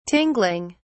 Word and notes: tingling: British English

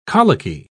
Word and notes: colicky: British English

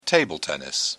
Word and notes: table tennis: British English

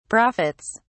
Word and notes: profits: British English

